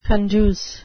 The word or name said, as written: conduce